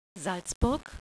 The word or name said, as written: Salzburg